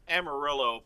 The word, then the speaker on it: Amarillo: It's pronounced Amarillo, but that's just...